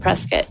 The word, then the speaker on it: Prescott: So, and here, it's spelled like Prescott, but... – That's how I was saying it. – Those of us here, locals call it Prescott.